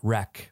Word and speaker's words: REC: Like, I think they, you say it Rec, but it's spelled like record.